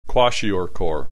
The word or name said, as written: kwashiorkor